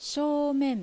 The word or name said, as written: Shoumen